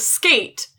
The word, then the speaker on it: SK8: Okay, we are going to watch the first episode of Skate, which is spelled SK8, the number 8, which is an anime about skateboarding.